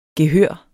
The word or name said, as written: gehør